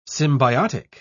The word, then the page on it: symbiotic: British English